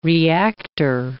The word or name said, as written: reactor